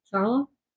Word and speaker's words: Ciarlo: I hope I'm saying his name right. C-I-A? – Ciarlo. – C-I-A-R-L-O. Is that how you say it? – Ciarlo.